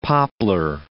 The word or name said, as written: poplar